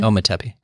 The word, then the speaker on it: Ometepe: In like just in Ometepe. Am I saying that correctly? – Yeah, I think so.